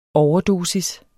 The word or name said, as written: overdosis